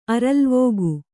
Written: ಅರಲ್ವಕ್ಕಿ

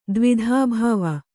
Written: ದ್ವೈಧೀಭಾವ

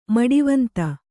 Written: ಮಡಿವಂತೆ

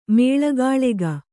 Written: ಮೇಳಗಾಳೆಗ

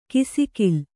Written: ಕಿಸುಗಲ್ಲು

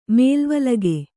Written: ಮೇಲುವಲಗೆ

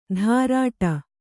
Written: ಧಾರಾಟ

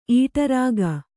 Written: ಈಟರಾಗ

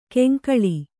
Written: ಕೆಂಕಳಿ

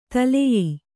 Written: ತಲ್ಲೀಯ

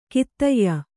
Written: ಖಾದ್ಯ

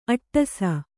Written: ಅಟ್ಟಸ

